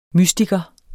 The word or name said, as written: mystiker